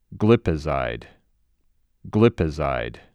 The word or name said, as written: GlipiZIDE